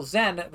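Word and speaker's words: Xen: I'm sorry, they're pronouncing the X with a Z sound. Okay, I understand from Admiral Zen that the Crucible project continues well, though Zen herself seems dissatisfied with the technological capabilities of the device.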